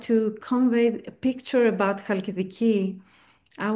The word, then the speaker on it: Halkidiki: Is it Halkidiki? – Halkidiki, yes. – Halkidiki, okay. – It's Halkidiki.